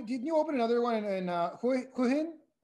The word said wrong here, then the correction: Hua Hin: How do you say it? – Yeah, I open a camp in Hua Hin now, by the beach.